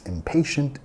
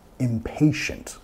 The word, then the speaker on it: impatient: Impatient. ¿Cuál sílaba estamos enfatizando? Impatient. La segunda, ¿okay?